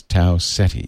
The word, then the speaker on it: Tau Ceti: the correct pronunciation of this star, pronunciation of the star is Tau Ceti. Ceti. – I say Seti.